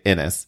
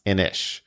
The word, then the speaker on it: Inis: I-N-I-S. So I was pronouncing it Innis. Apparently it's pronounced Inish. Mm-hmm. I would have never known, but Talking Meeples on Twitter let me know.